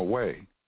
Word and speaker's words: Hui: It's up to that particular Suzu, also known as a we, in a we, no, way. And in traditional Chinese way, it's spelled H-U-I.